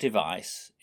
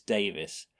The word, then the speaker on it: Device: It's not Device, it's Davis. East Lancashire can't pronounce their A's. Instead of saying David, they'll say David. And Devis, or Device, is probably Davies.